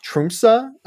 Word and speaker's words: Tromsø: It's funny enough, sold by a small Norwegian airline based out of, it's pronounced Tromsø. The airline is, I'm going to butcher this, Widerøe, W-I-D-E-R-O-E.